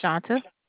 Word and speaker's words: Shaunta: My name is Shanta. – Can you spell that for me? – S-H-A. U-N-T-A.